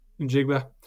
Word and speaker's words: Njigba: I'd love to see the rookie wide receiver, Jackson Smith, Nigba. Not sure how to pronounce that Njigba. – Right. Njigba.